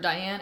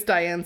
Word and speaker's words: Dyan: spell it D-Y-A-N? which I have never seen before as a spelling for Diane, and I thought it was Dylan without the L.